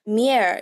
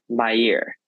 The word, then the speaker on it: Meir: And obviously this film is named Golda after Golda Meir. Is that how her last name's pronounced? – Meir. – Meir, thank you.